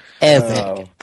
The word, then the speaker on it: Evangelion: No, Evangelion. It's a short E. Evangelion.